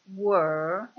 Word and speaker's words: were: E ancora, altra precisazione, la E di were è una E molto chiusa, diversamente dalla E dell'avverbio interrogativo dove, che in inglese si dice where.